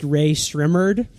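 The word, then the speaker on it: Rae Sremmurd: I did ask Twitter how to pronounce Ray Shrimmered, and I figured out it's eardrummers backward.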